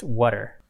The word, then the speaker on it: WUTR: Pronounced water. – Exactly. But it's spelled WUTR.